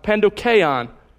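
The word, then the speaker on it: pandokeion (πανδοχεῖον): He uses the word pandokeion, is how it's pronounced, pandokeion.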